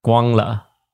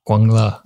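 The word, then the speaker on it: 光了: Guango. – I think that's supposed to be Guangla. I think the second G is silent.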